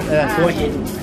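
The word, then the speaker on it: Hua Hin: I was wondering how you pronunciate Hoi Hin. – Okay. – Pronunciation Hoi Hin. Hoi Hin. – Okay, well, so far. – Yeah, so far. Hoi Hin. That's right, you pronounce it quite well. – That was a lucky one. I've been running everything else. It's like I've got no response at all. – Hoi Hin, Hoi is mean a head.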